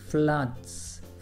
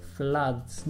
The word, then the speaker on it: floods: Floods. Floods. Not floods. Floods.